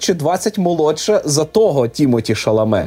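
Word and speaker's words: Timothée Chalamet: Тімоті Шаламе, правильно я вимовляю? – Так, ну, француз. – Тому, мабуть, він Шаламе.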